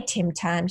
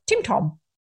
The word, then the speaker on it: Tim Tam: and she can't say Tim Tam, she goes Tim Tom.